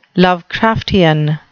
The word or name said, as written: lovecraftian